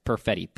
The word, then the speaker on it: Perfetti: Cole Perfetti, I believe is how you pronounce it. Perfetti. P-E-R-F-E-T-T-I.